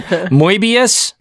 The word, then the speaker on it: Moebius: So is it Moebius? – It is when my ancestors came over to from Germany, but we've dropped the umlaut, and it's Moebius.